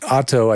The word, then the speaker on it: oto: Otto, I should say, is spelled O T O, acoustic. Auto, auto acoustic, not A U T O.